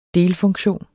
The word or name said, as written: delfunktion